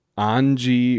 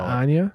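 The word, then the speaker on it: Anje: I believe Anji is technically the correct pronunciation, but it's not Anya. – I call her Anya, but I think it's supposed to be Anji. – Okay. – Like they said it was supposed to be like Schwabish German, like Southern German pronunciation, like Anji.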